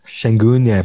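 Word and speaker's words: Şengün: And I guess if you want to throw Sengun, is that the way to pronounce it? – Sengun, yeah.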